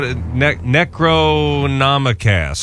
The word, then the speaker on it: Necronomicast: Am I saying that correctly? Necromacast? – You butchered it. – What? Necronomicast. – There you go. – Is that it? – That's it. Necronomicast. – I got to put an extra syllable in there.